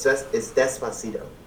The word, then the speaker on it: Despacito: Oh, no, it's Despacito.